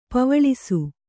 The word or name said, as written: ಪೆವ್ವಳಿಸು